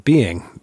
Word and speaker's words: Beyng: But if you listen to any Heideggerian scholar on YouTube or on their personal website, they tend to just pronounce it being, B-E-Y-N-G, being. B-E-Y-N-G is an Englishification of S-E-Y-N, which is the German.